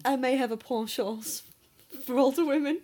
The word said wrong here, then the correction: penchant: Is that not how you pronounce it? Ponchance. I may have a penchant.